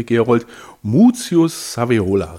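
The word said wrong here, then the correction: Scaevola: richtig? – Sevola. – Sevola. – Ja, Sevola. – Italienisch, Lateinisch, was ist das? Das ist Italienisch, Muzius Sevola. Gaius Muzius Sevola.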